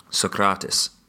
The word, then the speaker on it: Socrates: I have a friend from Athens and he really, really laughed the first time he heard me say Socrates. – How does he pronounce it? – Socrates. – Oh yeah, that does sound better.